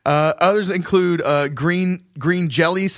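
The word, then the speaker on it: Jellö: And they were originally called green jello, but it was they had the umlaut over the O, and it was still pronounced jelly.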